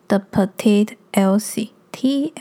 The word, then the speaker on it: The Petite Elsie: 那我的 IG 账号就是 The Petite Elsie，T H E P E T I T E E L S I E。